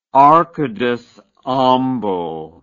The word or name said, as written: arcades ambo